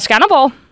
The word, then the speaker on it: Skanderborg: Øh, du er fra Skanderborg. – Det stemmer, og du udtalte det så dejligt korrekt.